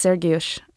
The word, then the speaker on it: Sergiusz: You'll notice that he pronounces Sergio's name differently. It's Sergiusz in Polish.